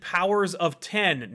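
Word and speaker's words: Powers of X: Neely says, have you heard Marvel pronounce it Powers of 10, no X? I have heard that, and I reject it.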